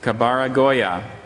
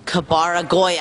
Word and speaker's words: kabaragoya: Kabaragoya. Kabara Goya.